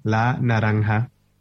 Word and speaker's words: La Naranja: Yeah, the newest song is called La Naranja. – La Naranja.